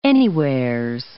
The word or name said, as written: anywheres